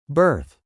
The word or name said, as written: berth